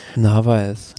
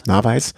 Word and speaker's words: Narváez: Ja, Schachmann, Narváez, wie spricht man ihn aus? Ich kann ihn nicht aussprechen. – Narváez. – Narváez.